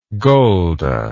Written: golda

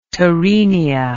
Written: torenia

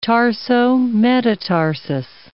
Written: tarsometatarsus

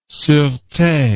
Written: surete

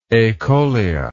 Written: acholous

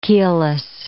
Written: keelless

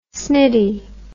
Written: snitty

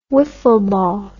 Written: wiffle ball

